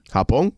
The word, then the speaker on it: Japón: Japón. That's how you say it in Spanish.